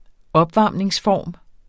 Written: opvarmningsform

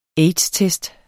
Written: aids-test

